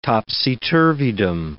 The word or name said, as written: topsy-turvydom